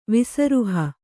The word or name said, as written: ವಿಸರುಹ